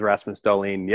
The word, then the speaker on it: Rasmus Dahlin: Also, you get to see Rasmus, like this year, you get to see the phenom of Rasmus Dahlin. I think that's how you pronounce his name. – Yeah, that's how I've heard it, is Rasmus Dahlin.